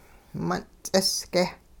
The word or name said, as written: mun' ts'isge'